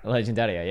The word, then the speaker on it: Legendaria: Legendaria, yeah. – That's exactly how you say it in Italian.